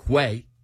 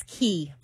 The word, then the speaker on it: Quay: They look like Quay. Q-U-A-Y. – That's what I pronounce. – It's a nice brand. – And Kelly says it's Quay. – It's Key. – Key. – Key. Like K-E-Y.